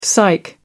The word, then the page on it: psych up: British English